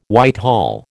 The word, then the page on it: Whitehall: American English